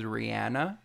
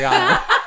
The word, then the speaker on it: Rihanna: Color is spelled C-O-L-O-R. – It kind of reminds me of like when Rihanna says her name, she says Rihanna. – And we all say it wrong. – And I'm like, oh, but she's saying her own name wrong. Like, it's definitely Rihanna.